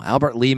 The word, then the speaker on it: Albert Lea: So come to Albert Lea, Minnesota. We're pronouncing it right, too. – We didn't do it before, but we're doing it now. Albert Lea, Minnesota.